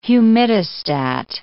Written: humidistat

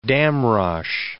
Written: Damrosch

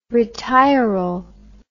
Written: retiral

